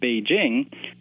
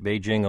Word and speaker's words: Beijing: Since we've adopted the word Beijing, I think a lot of people think that because it's a Chinese word, it should be pronounced in a foreign-sounding way. So I think a lot of people simply adopt a French-sounding jeu sound rather than the very simple English jing.